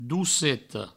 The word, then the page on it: doceta: douceto; Provençal